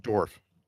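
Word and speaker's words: Dorff: Dorf. – Dorf. Okay, so you don't like put emphasis on the two F's at the end, like Dorf.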